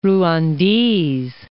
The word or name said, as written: Rwandese